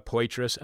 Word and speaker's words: Poitras: Laura Poitras.